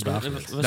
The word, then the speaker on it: Dagelet: ja, Dagelet of zo. Ik weet nooit hoe je zijn naam moet uitspreken. – Hans Dagelet.